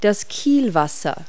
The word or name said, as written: wake